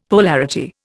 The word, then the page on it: polarity: Indian